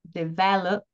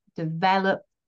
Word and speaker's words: develop: So if we take a word like develop, which has three syllables, develop, we can hear that my stress is on the second syllable, the middle syllable, but that's really carried in the vowel e.